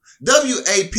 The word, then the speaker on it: WAP: WAP is WAP. It rhymes with cap, slap.